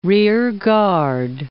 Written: rear guard

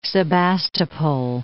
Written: Sebastopol

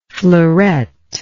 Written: fleurette

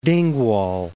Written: Dingwall

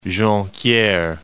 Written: Jonquiere

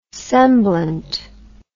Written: semblant

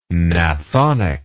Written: gnathonically

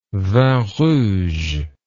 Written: vin rouge

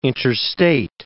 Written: interstate

